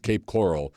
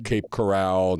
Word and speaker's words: Cape Coral: It's Cape Coral, mm-hmm. Not Cape Corral, not, right.